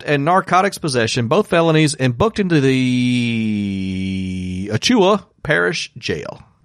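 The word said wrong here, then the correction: Ouachita: I said that wrong. – I wouldn't know how to say it right. – It's Ocheeta. So. Ocheeta Parish Jail.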